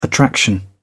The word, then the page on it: attraction: British English